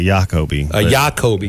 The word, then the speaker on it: Jacoby: It wasn't J-O, Kobe. – Like, yeah, I always think it's a soft J, like a Jacoby.